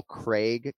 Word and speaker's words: Craig: desire to pronounce Paul Craig correctly, you just went Krieg.